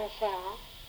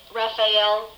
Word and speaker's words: Rafael: No, because people think it should be Rafael. – Right. – But I've always said Rafael. – But there are other people that say it that way too, obviously. So just two syllables instead of three.